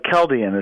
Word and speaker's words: Chaldean: Yeah, Chaldean is how we pronounce it.